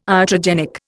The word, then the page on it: iatrogenic: Indian